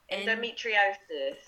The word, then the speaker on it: endometriosis: So this nicely brings us on to our next topic in Pregnancy After Loss episode, which is, I keep saying it wrong, endometriosis. – Thank you.